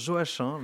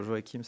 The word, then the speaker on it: Joachim: Voilà, Alors chez nous, on le prononce Joachim, mais euh, c'est pas grave, euh, Joachim, ça marche aussi.